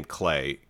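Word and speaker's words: Clé: So there is a voice actor named, I think it's, you pronounce his name Clay. It's Clay Bennett. So it's C-L-E with like a little apostrophe thing above it.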